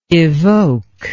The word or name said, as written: evoker